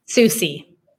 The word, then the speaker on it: Soucie: Susie.